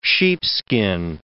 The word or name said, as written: sheepskin